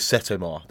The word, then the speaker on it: Setomaa: I've heard it pronounced a couple of different ways. Sometimes they say Seta, Seto, but I've gone with Setomaa. That's the one that seems to work for most people.